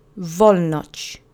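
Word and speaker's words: Wolność: The accent is on the first syllable of the word. Let's say it together three times. Volnoch.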